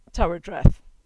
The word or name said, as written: Tywardreath